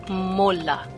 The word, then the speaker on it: Mola: Mola. – Mola? – Como Mola. se escreve? M-O-L-A. – M-O-L-A.